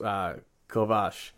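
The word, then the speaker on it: Kovář: VAR, but it's actually pronounced Kovach.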